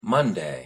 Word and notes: monday: English

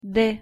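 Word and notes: д: Russian

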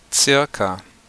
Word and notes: circa: German

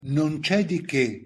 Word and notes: non ce di che: Italian

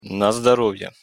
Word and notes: на здоровье: Russian

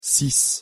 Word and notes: six: French

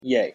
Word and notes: yea: English

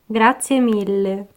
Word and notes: grazie mille: Italian

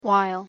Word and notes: while: English

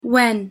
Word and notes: when: English

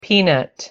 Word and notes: peanut: English

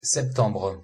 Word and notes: septembre: French

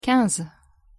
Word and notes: quinze: French

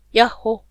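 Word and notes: ヤッホ: Japanese

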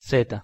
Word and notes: z: Spanish

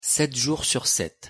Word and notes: 7 jours sur 7: French

